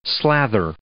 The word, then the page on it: slather: verb